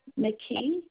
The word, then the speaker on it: McKee: Linda McKee. – You said Linda? – Yes. – And how McKee. – Did you spell it? McKee, M-A-K-E-E? – No, M-C-K-E-E.